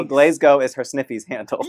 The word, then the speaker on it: Glasgow: I'm from Glasgow, not Glasgow. All you Americans, you foreign people to me, Lady Gaga came to Glasgow and she said, Glasgow. You all need to pronounce it better. Spell it like this, okay? In your head. G-L-A-Z. G-O. Glasgow. Glasgow. Say it like that.